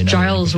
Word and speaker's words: Giles: And it's Giles with a G, for those of us who pronounce, GIF correctly as GIF.